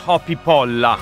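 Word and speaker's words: Hoppípolla: Questi sono i Sigur Rós, il trio islandese con Hopi Polla. Questa è la pronuncia ufficiale, sono proprio andato a vederla sul dizionario islandese italiano.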